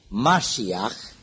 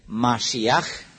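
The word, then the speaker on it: Mashiach (משיח): Mesia este un cuvânt ebraic, Mashiach, dacă vreți pronunția exactă, Mashiach, care însemnează unsul.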